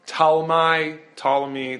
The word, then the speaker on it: Ptolemy: Ptolemy, the P is silent. I don't know how that works or why that works, but that's who we're talking about.